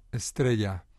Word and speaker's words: Estrella: The singer on this version of Lily that we're going to hear is Estrella Morente. Am I saying that right? – Estrella. – Estrella. – Yeah, Estrella, who means a star.